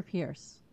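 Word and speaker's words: Peirce: I would love to see Hester Pierce, or Purse. I think she pronounces it Purse, who is the SEC commissioner who actually we like, the only one who, well, there's one more.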